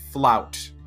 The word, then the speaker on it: fluyt: In a boat called the Flout, it's spelled F-L-U-Y-T, and this was key to the Dutch kind of expansion, the development of their trade networks.